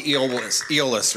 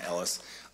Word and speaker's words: Eolus: It's actually Eolus, right? I was saying it wrong, Ellis.